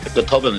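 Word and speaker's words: Go Tobann: Gotobun is how that's pronounced. Not Gotobun. Just Gotobun, all right? – Okay. – And it just means suddenly, so think about it. You know, so Gotobun. You say it fast.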